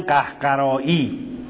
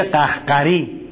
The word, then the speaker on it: قهقری: یا استصحاب قهقری که معمولا تو حوزه میگن قهقرایی، اگر بخوام تلفظ درست کرده باشیم قهقرا میدونید وقتی نسبت پیدا میکنه میشه قهقری، قهقری استصحاب قهقری.